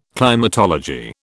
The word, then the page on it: climatology: British English